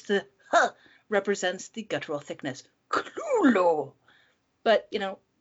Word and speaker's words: Cthulhu: Lovecraft's works are most popular for such terrors as The Great Cthulhu, or, you know, the story The Call of Cthulhu, and you can pronounce it any way you want. Some people say it's Cthulhu, Cthulhu.